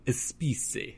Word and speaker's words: espice: the word spice comes from the old French word espice. Mm. That's how French words are pronounced.